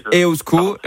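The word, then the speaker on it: eusko: Donc, quand on dépense un euro sous la forme d'un ESCO, il va circuler uniquement sur le territoire. – Non, mais vous faites bien déjà, Iban, vous dites EOSCO, donc on va déjà le prononcer correctement, ça c'est la moindre...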